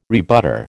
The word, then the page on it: rebutter: American English